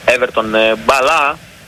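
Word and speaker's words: Everton Bala: Και τώρα Μαϊκόν θα δώσει τον Νέβες, Νέβες θα δώσει στον Δημητρόφ, Δημητρόφ στο Σεραφίμοφ, Σεραφίμοφ θα δώσει στον Έβερτον μπαλά, όπως προφέρεται το όνομα του Βραζιάνου επιθετικού.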